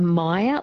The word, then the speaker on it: Meyer: So maybe a Meyer lemon, so M-E-Y-E-R. – That's it, yes. Excuse my bad pronunciation of it.